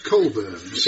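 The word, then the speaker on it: Cockburn's: Cobans. Thank you very much, old boy. Terrific stuff. – Oh, it's a pleasure. – I wonder why Cockburns is pronounced Colburns.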